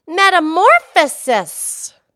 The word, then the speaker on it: metamorphosis: You know, metamorphosis has five syllables. Metamorphosis. I used to think it was metamorphosis, but it's not. It's metamorphosis.